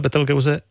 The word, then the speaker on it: Betelgeuse: Čím vás inspirovala hvězda Betelgeuse, jestli to říkám správně, to je výslovnost? – Ano, hvězda Betelgeuse ztratila jasnost, nebo potemněla výrazněji, než je obvykle.